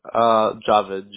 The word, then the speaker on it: Javed: Javed. J-A-V as in Victor, E-D.